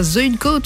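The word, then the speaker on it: Zuydcoote: Eh bien, moi, j'irais bien aller du côté de la France, à Zuidcoat, ou Zuidcoat, selon la prononciation, pour découvrir cette plage près de La Panne.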